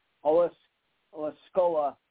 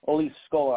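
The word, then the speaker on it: Osceola: Olascola Magic, I think it's pronounced, Steve. Yes, Scola. – Yeah, Luis Scola? – Olascola, I think it's how it's pronounced.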